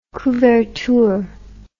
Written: couverture

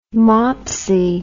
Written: mopsy

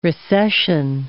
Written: recession

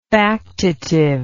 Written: factitively

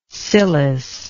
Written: cilice

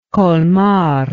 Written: colmar

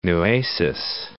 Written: Nueces

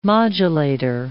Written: modulator

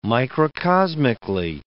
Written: microcosmically

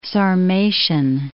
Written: Sarmatian

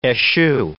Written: eschew

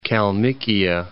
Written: Kalmykia

